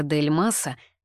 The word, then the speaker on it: Дель Мас: Старшего брата Николая, Артура Дель Маса, Дель Мас — фамилия французская, ударение на последнем слоге.